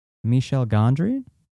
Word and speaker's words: Michel Gondry: You cannot talk about this song without talking about the music video, which was directed by French film director Michel Gondry.